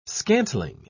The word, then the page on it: scantling: British English